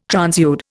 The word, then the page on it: transude: Indian